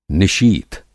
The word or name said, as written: निशीथ